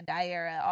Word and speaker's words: Diara: Well, one good thing is, like we were talking about earlier, maybe people will learn to pronounce my name. – Right. – Which is good, because I've been, you know, Daria, Diarrhea, Diara, all kinds of things. Mm-hmm. So it's nice that people will know Diara.